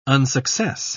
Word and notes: unsuccess: British English

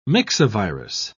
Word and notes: myxovirus: British English

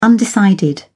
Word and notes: undecided: British English

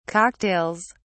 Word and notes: cocktails: British English

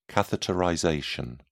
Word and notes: catheterization: British English

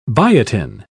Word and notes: biotin: British English

